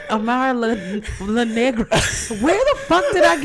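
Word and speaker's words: Amara La Negra: I never say her name right. Amara LaGrande isn't being extra at all. – Amara LaNegra. – Damn, I fucked that all up. – You said Grande.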